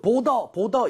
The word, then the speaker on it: border: 그런데 얘도 border 이렇게 하는 것이 아니라 D를 부드럽게 발음을 함으로써 리을 비슷하게 흉내를 내는 거에 더 추천이에요. 그래서 아무도 border 이렇게 하지 않고요. border 중간에 리을 비슷하죠. 그렇죠? 빠르게 내주시고 힘을 좀 빼고 발음해 주세요.